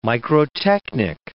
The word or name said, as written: microtechnic